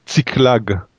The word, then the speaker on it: Ziklag: Ziklag.